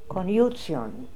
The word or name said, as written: Koniutsion